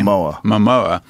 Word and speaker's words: Momoa: Momoa.